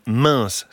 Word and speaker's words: Mens: Direction l'Isère ce matin, dans le village de Mince. Ça s'écrit M-E-N-S.